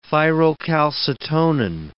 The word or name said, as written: thyrocalcitonin